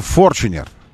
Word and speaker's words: Fortuner: И автомобиле, который напрямую конкурирует с еще одним претендентом на кошелек нашего слушателя-любителя рамных автомобилей, Toyota Fortuner. Именно так его нужно произносить. Это руководство компании Toyota и офиса Toyota российского попросили уже, выводя новое нынешнее поколение на рынок, они попросили, чтобы мы перестали звать его Fortuner, Fortuner, и звали его Fortuner.